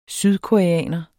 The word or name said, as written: sydkoreaner